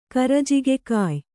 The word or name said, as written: ಕರಜಿಗೆಕಾಯ್